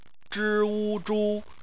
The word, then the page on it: zhu: bpmf